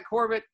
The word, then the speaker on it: Korbett: It's at Corbett. I have to pronounce both or spell both my names for you. At Corbett, KORBETT _ Koselak, KOSELAK on Twitter.